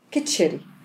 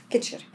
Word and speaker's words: كتشري: كتشري كتشري نطق كده كتشري